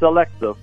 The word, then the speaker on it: Alexeeff: Am I saying that right, Victor Alexeeff? – You got that close. It's Alexeeff.